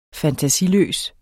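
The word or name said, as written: FANTASILØSE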